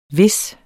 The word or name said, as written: hvis